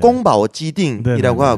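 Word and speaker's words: 꽁바오 지딩이라고 (宫保鸡丁): 궁보개정인데 중국어 발음으로는 꽁바오 지딩이라고 하고 그리고 이제 피디님께서도 말씀해 주셨지만 그 찌 그리고 개가 닭고기를 뜻하는데 정이라고 하는 게 그 띵인데 네네.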